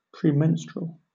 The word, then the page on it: premenstrual: British English